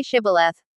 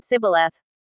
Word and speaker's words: Shibboleth: Then they said to him, Say Shibboleth. And he said Sibboleth. Because he could not pronounce it right.